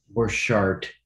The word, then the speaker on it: Borchardt: And in so many ways, so when I say he, we haven't said the main person, the main focus of this is Mike Borchardt, I believe is how you pronounce his name. Borchardt, right? – Sounds right.